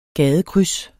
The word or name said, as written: gadekryds